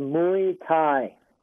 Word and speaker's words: Muay Thai: It's called Muay Thai.